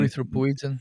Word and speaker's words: erythropoietin: It can over time increase, let's see if I can say it right, erythropoietin, EPO, you know, the blood doping thing. – Erythropoietin.